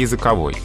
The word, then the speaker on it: языковой: А если у нас э, мы говорим о языке в значении средства общения, вот от этого э, существительного ну, язык в этом значении образуется прилагательный языковой.